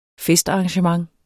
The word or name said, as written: festarrangement